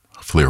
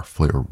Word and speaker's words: Llyr: Fleer Williams, the pianist. His name is spelled L-L-Y-R. Fleer Williams.